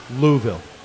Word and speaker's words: Louisville: And by the way, it's Louisville. – It is Louisville. That's how you pronounce the capital.